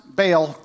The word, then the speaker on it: Baal: I had somebody text me, I've always pronounced Baal. That is the American version of Baal. Okay, if you want to pronounce Baal right, you have a hiccup in the middle of it. Okay, Baal. Okay, that's how that works.